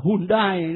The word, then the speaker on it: Hyundai: xe hơi hân đê đó. Việt Nam mình đọc là Hyundai đó.